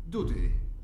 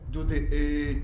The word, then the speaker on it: dudy: Doodle. – Dudy. – Doodlesack. – No, not doodle. – Okay. – Dudy.